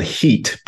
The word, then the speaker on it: HIT: It was called HEAT, but it's spelled HIT.